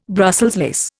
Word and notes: Brussels lace: Indian

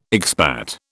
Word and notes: expat: British English

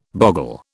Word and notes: bogle: British English

